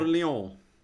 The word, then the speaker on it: Orléans: Orléans. Bra uttal där.